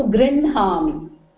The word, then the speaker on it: गृह्णामि: All these are anunasika swaras, and wherever they come with a, they are pronounced first, although her is written first. Okay, so grinhami.